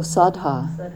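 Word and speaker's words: saddhā: Sudha, I think I'm saying that right. What was the verb for this? Oh, sadha. – Sadha. – It's S-A-D-D-H-A, just for your note-taking.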